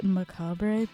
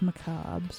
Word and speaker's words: macabre: Oh, macabre. I always called it macabre, but it's macabre, so...